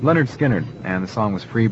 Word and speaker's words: Lynyrd Skynyrd: It's by Lynyrd Skynyrd.